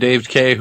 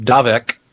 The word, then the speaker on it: Dave K: One of my favorite posters, a man named Dave K, who in my mind is always pronounced Davek. – Uh, that's what uh, I thought said, it was. I thought so too, but it's apparently Dave K.